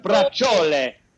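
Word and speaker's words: braciole: It's brajol. It's not brajol. Okay. Brajol. Brajol. It's actually pronounced brajole. Brajole.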